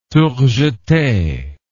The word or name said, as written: tour jete